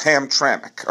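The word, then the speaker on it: Hamtramck: He said it's pronounced Ham, like Cole Hamels, Tram, like Alan Trammell, and Mick, like Mickey Mantle. So it's Ham Tramick.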